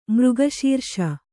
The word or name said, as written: ಮೃಗಶೀರ್ಷ